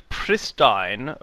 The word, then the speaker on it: pristine: It informs announcers that pristine rhymes with wine, respite is pronounced as if there were no E.